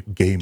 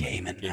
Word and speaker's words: Gaiman: Excuse me, Gaiman. – Gaiman, I'm sorry. Yep. Neil Gaiman. – The man himself gave us his blessing. We should try to pronounce it correctly.